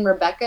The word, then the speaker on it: Rebekah: Jillian Rebekah and Rebekah spelled with a K and an H, so just nice.